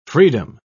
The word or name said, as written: freedom